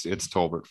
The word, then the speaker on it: Tolbert: Yeah, it's Tolbert for sure. But for the French listeners, you can add a little accent on there.